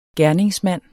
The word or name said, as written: gerningsmand